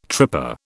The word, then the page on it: tripper: British English